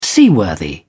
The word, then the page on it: seaworthy: British English